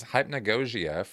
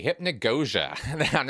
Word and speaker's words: hypnagogia: Now, I might be mispronouncing that for some folks out there. I looked it up, actually, so I think I'm saying it right. It's kind of an odd word, hypnagogia or hypnagogia, referred to as also hypnagogic hallucinations.